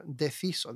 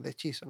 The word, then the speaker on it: deciso: Por ejemplo, aquí en la introducción de la marcha está escrito deciso, que se deciso, deciso, ¿no? La C en italiano se pronuncia como una che, ¿no?